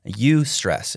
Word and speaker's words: eustress: It's called eustress. It's spelled E-U and then the word stress.